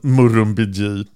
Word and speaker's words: Murrumbidgee: Och jag fick en um, ut uttalsanvisning. Murrumbidgee, ja.